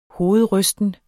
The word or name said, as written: hovedrysten